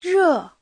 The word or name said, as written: re4